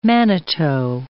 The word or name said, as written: manito